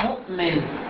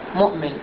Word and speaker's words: مؤمن: الواو والياء وهما كرسي للهمزة، مثل مؤمن، مؤمن، احنا عند النطق لا ننطق الواو، نقرأها مؤمن مؤمن، إنما الواو هي فقط شكل من أشكال الهمزة. الهمزة لها أشكال مختلفة، همزة على الألف، همزة على السطر، همزة على الياء أو همزة تحت الياء، همزة على الواو، همزة تحت الألف.